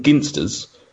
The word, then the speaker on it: Ginsters: It's a forum where Vertical Gyro has asked the question, when pronouncing ginsters, those fine purveyors of meaty products to fat cunts, is it a soft G as in gin or a hard G as in gamble? And all of the users have confirmed that it is a hard G as it's named after the Ginster family.